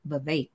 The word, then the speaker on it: Vivek: He said it rhymes with cake, Vivek um, all flash, no substance.